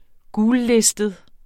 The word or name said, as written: gullistet